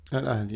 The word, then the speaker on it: Al Ahli: Al Ahli, yeah. – Is my pronunciation not terrible? – Fantastic.